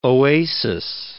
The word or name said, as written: oasis